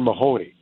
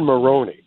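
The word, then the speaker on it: Moroney: Bailey Zimmerman, Riley Green, Megan Maroney, I always get her name wrong. Megan, I want to call her Megan Mahoney. Megan Maroney.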